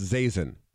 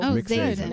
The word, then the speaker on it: Zazin: And I'm told it's Zazin. Rhymes with raisin. There we go. – Oh, Zazin.